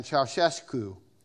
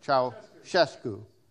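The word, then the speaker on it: Ceaușescu: and I'm going to try to say his name as best I can, Ceausescu. Doesn't look like that, but that's how they say you say it. Ceausescu.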